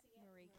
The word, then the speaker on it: Marieke: I'm pronouncing it Marieke. You want to say it back? – Micah? – No, Marieke. – Morica? – No, Marieke.